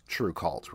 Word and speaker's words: TRVEKVLT: And just to clarify for anybody listening who wasn't watching earlier, it's spelled TRVEKVLT, but pronounced True Cult, right?